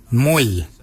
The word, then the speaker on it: mooly: Warrigal is spelled W-O-R-R-A-G-O-L, warrigal. Warrigal is your upper mouth. For your nose, we say moil. Moil is your nose. Moil is spelled M-O-O-L-Y.